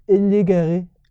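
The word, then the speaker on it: loup-garou: Lou Garou. – Lou Garou. – That's how it's pronounced. – All right. It kind of sounds like Louis Roux.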